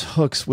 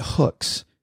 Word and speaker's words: Hooks: But Paula, people around here pronounce Hooks with like three syllables. It isn't Hooks, H-O-O-K-S, which is the way you spell it. It's like Hooks. I cannot pronounce it correctly.